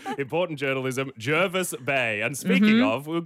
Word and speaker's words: Jervis Bay: Your auntie texted you moments after that saying, can confirm it's Jarvis, 100% Jarvis.